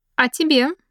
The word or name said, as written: about you